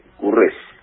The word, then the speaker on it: Corrugés: Espero haber pronunciado bien su apellido. Pierre, ¿cómo le va? – Buen día, Marcelo, buen día al país. Sí, apellido francés, Corrugés.